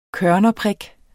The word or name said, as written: kørnerprik